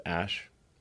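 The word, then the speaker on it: Æ: And then the AE is like pronounced Ash.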